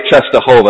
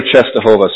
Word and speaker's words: Częstochowa: If I spoke Polish, then probably be able to pronounce it better, but it's Our Lady of Częstochowa. That's how I understand you pronounce it. Our Lady of Częstochowa.